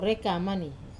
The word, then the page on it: rekamani: wamesa